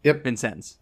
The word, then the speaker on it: Vincennes: And then you got Jack Vincennes. Is that right? – Yep. – Vincennes. Okay, I have to make sure I say it right.